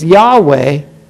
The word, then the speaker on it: Yahweh: And as you go through these letters, making up the word as we pronounce it is Yahweh, Yahweh takes his name and he gives his two hays.